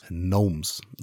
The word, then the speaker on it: gnomes: Aber der nächste, The Gnomes. Gnomes. The G is silent.